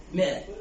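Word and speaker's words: مائة: المئة تلفظ مئة بس تكتب شنو؟ ماء. وش تلفظ؟ مئة.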